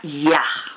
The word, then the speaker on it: Yah: Vean que dice Jojma y está la Yud y la Hei y se pronuncia Yah.